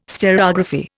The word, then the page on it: stereography: Indian